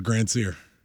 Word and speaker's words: Grand Seer: Lord Grand Seer. Erica corrected me. We don't pronounce it. Of course. You don't pronounce the D. Grand Seer. It's Grand Seer. – That makes sense because it's the Grand Prix.